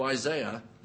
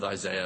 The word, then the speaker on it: Isaiah: And yet, in his writing, he refers to Isaiah. Sorry, I slipped back into my American pronunciation. You'll have to live with Isaiah. It might stick that way.